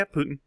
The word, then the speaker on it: Putin: Yeah, Putin.